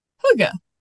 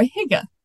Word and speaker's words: hygge: And I'm going to talk some today about the concept of hygge, or hygge, but the book from Bard about hygge says it that way, so I'm going to try to say it that way. And it's spelled H-Y-G-G-E, and it's the little book of hygge, and the book of hygge.